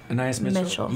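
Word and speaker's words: Anaïs Mitchell: Anna Anais Mitchell.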